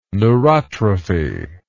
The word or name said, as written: neurotrophy